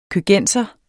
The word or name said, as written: køgenser